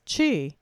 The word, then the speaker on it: qi: In acupuncture, the Chinese call this life force qi, and in Japan, it is also called qi, but it is spelled Q-I, as in qigong.